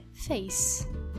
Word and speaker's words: face: Por exemplo, a palavra face, nós tendemos a falar face, pronunciando esse E, porque no português nós não temos vogais mudas, mas a pronúncia correta é face, ou seja, o som final é do C, face.